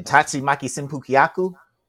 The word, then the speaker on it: Tatsumaki Senpukyaku: I know the Hadouken, the Dragon Uppercut, the Tatsumaki Senpukyaku, all of that mess. – You pronounced that fucking perfectly.